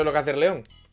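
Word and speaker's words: Leon: No se dice Lion, tío, se dice León. ¿Cómo que Lion? – ¿El, León? ¿no? Pero es que es León en inglés también.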